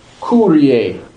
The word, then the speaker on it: Kyrie: You'll pronounce this as Kyrie, ooh. You'll do an ooh sound.